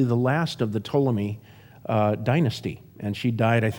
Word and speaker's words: Ptolemy: One is named Ptolemy, and it's spelled Ptolemy. Like pneumonia is spelled pneumonia.